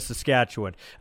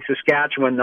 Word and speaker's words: Saskatchewan: And they say Saskatchewan, not Saskatchewan. Which, yeah.